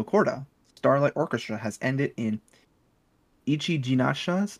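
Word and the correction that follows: Ichijinsha's: Oh, excuse me. – Ichijensha's. I said it wrong. – Ichijensha's. There you go. That's how you say it.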